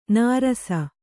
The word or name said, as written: ನೆರಸು